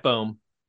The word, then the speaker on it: Boehm: Boehm? – Yes, Jeff Boehm.